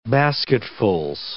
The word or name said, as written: basketfuls